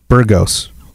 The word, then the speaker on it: Burgos: Burgos is, am I saying that right? Burgos. – Yeah. – I'm pretty sure. – That's how I would say it, yeah.